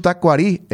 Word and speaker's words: Tacuarí: La pronunciación del club Tacuarí, el acento debe ser en el idioma guaraní, dice.